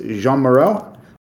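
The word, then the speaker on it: Jeanne Moreau: It is Jean Moreau.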